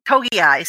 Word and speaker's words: Togiai: One is Wyatt Davis, the guard, and then the defensive tackle, Tommy Togiai, I believe is how you pronounce it. – Togiai, sorry.